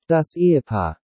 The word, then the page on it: Das Ehepaar: Deutsch